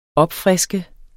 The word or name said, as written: opfriske